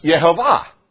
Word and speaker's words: Jehovah: So you see J-E-H-O-V-A-H. And it's pronounced Yehovah. And we say Jehovah. It's a hybrid name, combining the vowels with the tetragrammaton. That's where Jehovah comes from.